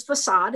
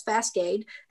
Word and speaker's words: facade: And I said it was facade, and I said it as fascade.